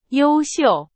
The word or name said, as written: 优秀